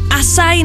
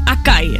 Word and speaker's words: açaí: Açaí nebo açaí bowl a ne acai. Někdo říká acai bowl, tak je to açaí, açaí.